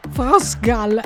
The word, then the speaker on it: France Gall: Et France Gall. – Ecco. – J'ai bien compris, David Benn, Music ABC, France Gall. – France Gall, ecco, credo che ci rimarrà veramente nella testa ormai.